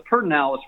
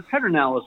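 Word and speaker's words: Pedernales: put an R in the in that word, the Pertinalis River, instead of the Pedernalis River.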